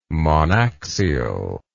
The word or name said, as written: monaxial